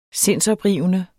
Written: sindsoprivende

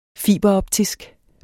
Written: fiberoptisk

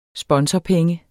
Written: sponsorpenge

